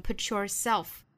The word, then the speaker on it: put yourself: You just 若读成 just, you just have to 若读 put yourself, put 和 yourself 连读成 put yourself. 注意 t 和 y 连读同化成 ch.